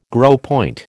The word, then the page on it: gros point: American English